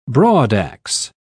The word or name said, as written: broadax